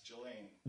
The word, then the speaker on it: Ghislaine: It's Ghislaine. Is that how you pronounce her first name? – I don't think there's so much S. – Ghislaine.